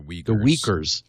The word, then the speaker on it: Uyghurs: The Uyghurs.